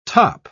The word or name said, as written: top